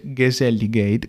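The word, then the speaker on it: Gezelligheid: Però l'ultima cosa che volevo dire nell'introduzione e anche quella sensazione di comfort e convivialità che si dice Geselligheid, che sono andato a cercare la pronuncia, cioè il benessere degli ambienti familiari.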